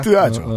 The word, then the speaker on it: THR: 뭐라고 읽어요? 뜨아. 야 뜨아죠.